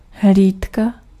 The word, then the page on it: veille: France; [vɛj]